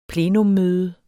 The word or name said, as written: plenumdiskussion